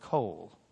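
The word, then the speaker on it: kol (קול): You don't pronounce the vav here. It just has an O sound. It takes a vowel sound. Then you've got the L at the end, kol.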